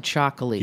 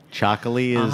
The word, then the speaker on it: txakoli: I'm like back into Chocoli. – You have to spell that for everyone. – T-X-A-K-O-L-I. – Chocoli is the grape?